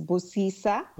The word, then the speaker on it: Busisa: Yeah, so it is, we are incorporated in the state of California, but the foundation, it's the pronunciation is Busisa. – Busisa, okay. – And Busisa, yes, Busisa actually means blessings.